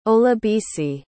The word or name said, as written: olabisi